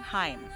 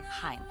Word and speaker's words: Haim: Haim. – Haim.